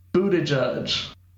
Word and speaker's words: Buttigieg: No, it's Buttigieg.